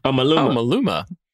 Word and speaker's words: Maluma: Maluma.